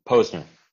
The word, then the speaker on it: Posner: Posner.